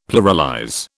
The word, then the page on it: pluralize: British English